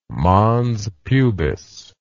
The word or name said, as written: mons pubis